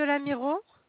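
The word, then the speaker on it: Lamirault: Non, c'est 2 rue de l'Amiro.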